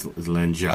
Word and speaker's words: Langella: You said Mangella. Langella? He's long dead. – Langella. Langella. Did I say his name wrong for my entire life? Langella? It's not right? – It's Langella. – Of course it isn't. Why would it? Why would it be right? – People expect this from you. It's all good. – It's a soft G.